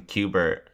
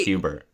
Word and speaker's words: Hubert: And he reveals that it's not Fry, it's his clone, Q-Bert, and he's in a glass tube. – Q-Bert. Hubert J. Farnsworth. – It rhymes with Hubert.